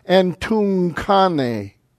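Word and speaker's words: Entunkane (ἐντυγχάνει): Here you have the gamma ki together, pronounced as an N, CH sound, like a ki. Entunkane.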